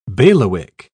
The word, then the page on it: bailiwick: British English